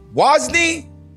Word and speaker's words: Wosny: I was expecting you to say my name like, Wosny, like how you do David, but I guess I wasn't.